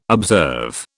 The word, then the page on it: observe: British English